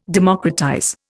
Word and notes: democratize: Indian

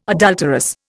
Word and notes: adulterous: Indian

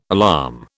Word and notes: alarm: British English

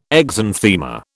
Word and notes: exanthema: British English

